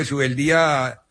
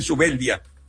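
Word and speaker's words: Zubeldía: Pero yo sé que hablamos de Subeldía con tilde en la I, como se pronunciaba antiguamente, porque ahora los relatores le dicen Subeldía.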